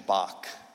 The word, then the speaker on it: Bach: A man named Johann Sebastian Bach. I know in Germany they would say it different, but I attempt to speak English. And so I'm going to pronounce it like you would in English.